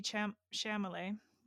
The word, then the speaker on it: Chalamet: With her new lover, Yuul, played by Timothy Chalamet. sorry. How do I say his last name? – It's like it's Charlemagne, but that reminds me how people mess up like Noah Centennial.